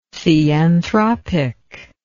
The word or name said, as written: theanthropic